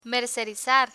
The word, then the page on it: mercerizar: Spanish